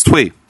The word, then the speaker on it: Thuy: I thought he was trying to pronounce T-H-U-Y. – T-H-U-Y. It's Twee.